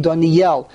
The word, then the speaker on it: Doniel: Okay, Doniel. The emphasis is on the Yud. – And the is under the Yud.